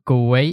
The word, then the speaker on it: Galway: And today from Galway in Ireland, have I pronounced that right? – Yeah, Galway, perfect. Galway.